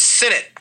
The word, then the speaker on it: Sinnott: It is Sinnott. – Sinnott. – Rhymes with minute.